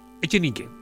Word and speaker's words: Echenique: Cecilia Echenique. ¿Está bien pronunciado? ¿Echenique o Echenique? Echenique. Bien.